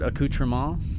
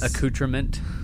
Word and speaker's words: accoutrement: But he mispronounces the word accoutrement. – Oh, well, that's he says accoutrement. – Yeah, I can see that because I didn't know that it was actually pronounced as accoutrement.